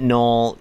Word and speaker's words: Gnoll: G-N-O-L-L. I assume that a Knoll is a... I assume that Knoll is... like, that it's pronounced Knoll, but it's spelled G-N-O-L-L.